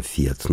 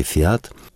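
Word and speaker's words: Fiat: Поэтому еще с первых десантов автомобильного концерна «Фиат», ударение на первый слог в итальянском «Фиат», но можно и по-русски «Фиат», все корреспонденции, журнальные, а сейчас и книги стали выходить, мемуары об итальянцах в Тольятти-граде.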